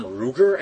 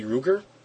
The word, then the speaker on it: Ruegger: That episode is written by a guy by the name of Tom Ruger, I think is how it'd be pronounced, R-U-E-G-G-E-R, would that be Ruger? – Right, I think so.